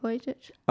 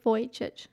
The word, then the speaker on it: Vujicic: Nick Vujicic.